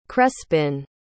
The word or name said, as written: crespin